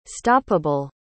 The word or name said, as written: stoppable